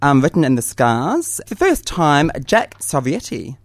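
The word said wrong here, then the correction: Savoretti: Did I say it right? – Yeah, you did. Close. It's a tough one. – Jack Sovietti. – Savaretti. – Got it. – Yeah. People mix it up with Pavarotti.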